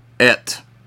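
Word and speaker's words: Et (את): It's the word Et. E-H-T is how it be pronounced.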